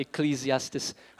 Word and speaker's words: Ecclesiastes: Ecclesiastes.